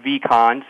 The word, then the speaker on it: Vehcon: Well, the company is called Vehcon, short for Vehicle Connections.